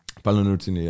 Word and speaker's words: Paolo Nutini: You did this, I don't know how to pronounce it, Paolo Nutini? – Paolo Nutini, yeah.